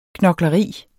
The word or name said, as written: knokleri